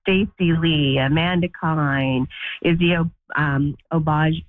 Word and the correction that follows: Abouaji: Oh, I can't ever pronounce her name. – Oh, Abouaji. – Yeah, that's it.